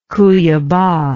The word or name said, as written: cuiaba